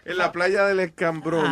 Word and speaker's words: Escambrón: Ah, en la playa del escabrón. – No, no es cabrón. Oh, ok, you see, el hecho que tú pongas acento en inglés no está corrigiendo la vaina. Escambrón. – No escabrón. Ok.